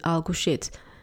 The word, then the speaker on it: Alcochete: But if you don't, this is how it's spelled. A-L-C-O-C-H-E-T-E. Alco shit. Okay. Oh, goodness, sounds terrible.